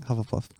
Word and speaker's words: Hufflepuff: Hufflepuff. Yeah. That's how you say it. Hufflepuff.